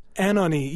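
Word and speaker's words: Anohni: Anoni, am I saying this right? This is another one that's new to me. – Anoni, yeah.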